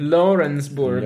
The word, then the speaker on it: Lawrenceburg: En Lawrenceburg. No sé si lo he dicho bien. – Lawrenceburg. Lawrenceburg. – Lawrence, pues eso.